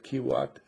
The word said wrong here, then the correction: Kuwait: Kuwait is a third world nation?